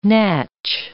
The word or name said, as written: natch